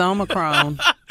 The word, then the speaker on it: Omicron: Not our president was just mispronouncing Omicron. – It's Omicron.